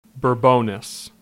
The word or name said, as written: Bourbonnais